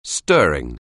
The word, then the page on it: stir: anglais